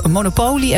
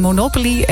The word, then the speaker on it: Monopoly: Pascal zegt bijvoorbeeld, ja, Monopoly en Monopoly, het is beide juist uitgesproken.